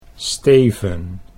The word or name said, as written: Steven